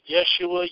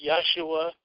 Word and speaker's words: Yeshua: It's pronounced Yeshua, Yeshua, something along those lines.